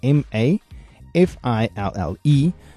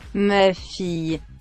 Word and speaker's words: ma fille: The French spelling for my daughter is spelled M-A and then F-I-L-L-E. So two words, M-A, F-I-L-L-E, and sounds something like this. – Ma fille. Ma fille. – And the French spelling for my son is M-O-N-F-I-L-S.